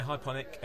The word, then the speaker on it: Hyponik: So start off just talking about Hypernic, which I think I'm saying right. Is it Hypernic or Hyponic? – Yeah, Hyponic, H-Y-P-O-N-I-K.